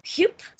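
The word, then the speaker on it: Huub: And his name is Felix Adelar, and he's played by Hupe Staple. I had to look up how to spell Hupe, or how to pronounce Hupe. It's H-U-U-B, but apparently that's pronounced like H-U-Y-P.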